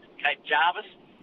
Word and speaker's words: Cape Jervis: Are you Cape Jervis or Cape Jarvis? – Cape Jarvis. – Yes, I'd say Cape Jarvis